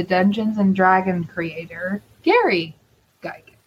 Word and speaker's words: Gygax: Psycho Goreman is from the planet Gygax. Did I say that right? – Yep. – Okay. From this planet Gygax.